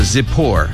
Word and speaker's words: Zippor: Balak, Balak, B-A-L-A-K, Balak. 이렇게 연습해 주시고 십볼은 Z로 시작합니다. Zippor, Zippor. 십볼을 Zippor 이렇게 연습해 주시기 바랍니다.